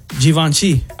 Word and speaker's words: Givenchy: Givenchy.